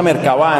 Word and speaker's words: Merkaba: Una de las bibliotecas digitales católicas más impresionantes se llama Merkaba, se pronuncia Merkaba en realidad, pero no tiene tilde.